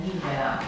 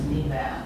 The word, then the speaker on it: Nivea: De la nix nivis și numele acelor produse cosmetice Nivea. Deci accentul e Nivea, e Niveus Nivea, nu Nivea, se pronunță de obicei.